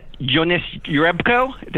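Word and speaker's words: Jonas Jerebko: In early August, he predicted that, this is jumping ahead a little bit, one of my favorite predictions, that Jonas Jurebko, did I say that right? – Yeah.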